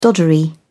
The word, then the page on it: doddery: British English